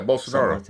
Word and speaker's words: Bolsonaro: So the first speaker of this video used an example of the president of Brazil, and I can't pronounce his name, save my life, but it's like Hair Bolsonaro? Bolsonaro? – Yeah, Bolsonaro.